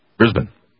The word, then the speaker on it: Brisbane: You know that's Brisbane, not Brisbane? – Brisbane. – It's not Brisbane. – Brisbane. – You're saying it so fast because you don't know how to say it? It's Brisbane. – No, I would have said right. Brisbane. – We would have. Okay. – I would not have said Brisbane.